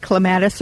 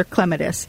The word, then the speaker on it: clematis: One is more American. They're both fine. It's like clematis or clematis. You can do either one. In the United States, normally we are emphasizing the second syllable. And in Britain, in England, it's the first syllable.